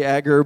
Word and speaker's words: Agur: Maybe it is A-G-U-R, maybe you say Agur, but the correct pronunciation according to my extensive research on the internet, so it's got to be right. It's Agur, pronounced A-W-G-O-O-R.